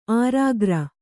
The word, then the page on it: ಆರಾಗ್ರ: ārāgra